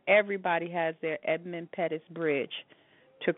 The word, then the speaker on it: Edmund Pettus: Everyone in this country, black, white, you know, whatever your nationality is, whatever your political party is, everyone has an Edmund Pettus in front of them right now. I think I'm saying the name right, Edmund Pettus. – Yep.